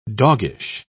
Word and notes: doggish: British English

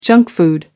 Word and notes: junk food: American English

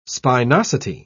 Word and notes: spinosity: British English

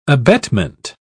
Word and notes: abetment: British English

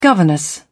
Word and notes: governess: British English

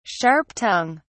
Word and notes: sharp tongue: British English

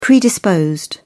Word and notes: predisposed: British English

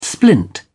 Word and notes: splint: British English

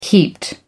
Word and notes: heaped: American English

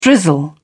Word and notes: drizzle: British English

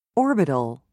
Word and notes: orbital: British English

